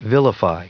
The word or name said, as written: vilify